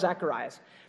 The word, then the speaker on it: Zacharias: Because when the angel tells Zacharias, or that's the Greek way of pronouncing his name, Zechariah is the Hebrew name way, so I'll probably toggle back and forth, you know.